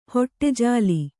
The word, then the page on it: ಹೊಟ್ಟೆಜಾಲಿ: hoṭṭe jāli